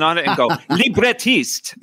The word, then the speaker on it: librettist: It's a librettist.